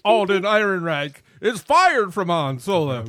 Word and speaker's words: Ehrenreich: Einrich? – Say it like it's spelled. – Ehrenreich. Thank you.